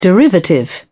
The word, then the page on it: derivative: British English